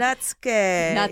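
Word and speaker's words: Netsuke: Can you tell us what Natsuki is? Am I saying that right? – Oh, yes. Yeah. Natsuke. – Yeah, okay. Natsuke. So I'm saying it wrong.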